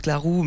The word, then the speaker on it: Claror: Alors ça se prononce Clarou, mais ça s'écrit Claror, parce qu'en fait c'est de l'occitan.